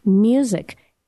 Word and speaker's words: Muzik: Anyway, this guy, his name is Bruce, which is spelled normally Bruce, and his last name is Music. M-U-Z-I-K, Bruce Music.